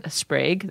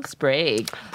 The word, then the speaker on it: Sprague: Sprague? That's how you pronounce it? – You got it. – Nice. – Sprague. – Prague with an S.